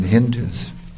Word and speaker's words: Hindu: They're called Hindus because they pronounce the H like S, they pronounce the S like H, so they said Hindus.